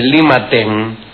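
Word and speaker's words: Lima Ten (利瑪竇): Matteo Ricci, que en chino se dice Lima Ten, si usted se llama Matteo Ricci, vamos, clase de chino.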